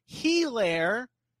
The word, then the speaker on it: Helaire: Helaire over under 6 receptions. – The H is silent, buddy.